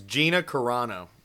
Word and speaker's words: Gina Carano: Oh, we all got her name wrong. It's Gina Carano. – Oh, I said Gina Carla. I biffed it completely.